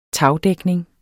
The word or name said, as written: tagdækning